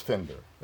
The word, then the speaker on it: Finder: It is Finder. It is. It rhymes with Tinder and Cinder.